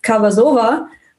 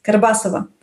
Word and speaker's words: Karbasova: So my name in German sounds like Karbasova, which is also a bit different, right? And the Russian version, like the original one is Karbasova. So the tone of a pronunciation is totally different.